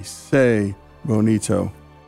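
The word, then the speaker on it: Tse Bonito: It's spelled T-S-E, Say Bonito.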